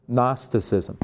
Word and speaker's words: Gnosticism: Gnosticism. Now that's kind of a fancy word. Think of it like this: Gnostic with ism after it. And Gnostic is spelled G-N, Gnostic.